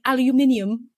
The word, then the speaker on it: aluminium: When British people say aluminium. – That's how they pronounce it, though. That's correct.